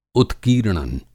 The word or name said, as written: उत्कीर्णन